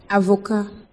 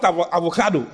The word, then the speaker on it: avocat: Avocat. – Avocat. Avocat. Not avocado. Avocat.